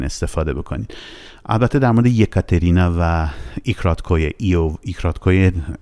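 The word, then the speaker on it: یکاترینا (Екатерина): مثلا شما اگه کسی اسمش کاترین باشه، اسم واقعیش میشه یکاترینا، یکاترینا، یکاترینا یه هستش اولش، حرف ای انگلیسی یه، ولی یه تلفظ میشه، یه ایکراتکایه نیستش، میشه اونم نوشت ولی غلطه.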